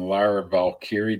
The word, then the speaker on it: Lyra Valkyrie: So we're going to get some interference there from Lyra Valkyrie. Did I say it right? – That was pretty good.